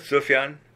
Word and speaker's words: Sufjan: I mean, to me, the Mystery of Love, this Sufjan Stevens, is that how you pronounce it? Sufjan? – Yeah.